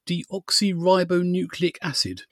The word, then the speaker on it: deoxyribonucleic acid: And it's about this thing called, I'm going to try and pronounce this correctly, deoxyribonucleic acid.